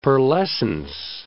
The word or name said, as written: pearlescence